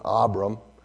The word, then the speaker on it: Abram: His name was Abram or Abram. That's the correct pronunciation, Abram.